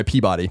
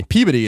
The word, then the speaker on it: Peabody: Peabody. Peabody is a town. That's how you say it. Sure. I'm sure it is. – It is. And that's how you say it. It's in Massachusetts. – Okay. Sure it is. Just like Wichita and a whole bunch of other ones you got there. – So Peabody is how you say the award. You're right. But Peabody is how you would say the town.